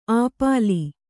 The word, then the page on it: ಅಪೀಲ್: kannada